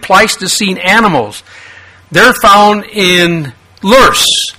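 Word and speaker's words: Löss: This is actually a Lurs, that's pronounced Lus by Americans and Lurs by Germans, is a German term.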